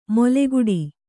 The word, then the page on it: ಮೊಲೆಗುಡಿ: kannada